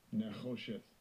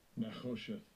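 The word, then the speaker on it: Nechosheth (נְחֹשֶׁת): Now, brass is pronounced this way in the African Hebrew language. – Strong's H5178. Nechosheth. Nechosheth.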